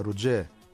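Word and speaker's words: Roger: Ρότζερ το λέμε εμείς, αυτός το λέει κάπως περίεργα. Ρουτζέ, Κάπως έτσι το προφέρει ο Γκριμάου.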